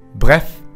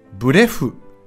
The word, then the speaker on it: bref: フランス語では一体どのように言うでしょうか正解は Bref Bref Bref 発音も短く一息で Bref ブレフとならないように気をつけましょうとにかく要するにという意味の副詞で、通常、まとめて言いたい文の最初に言います。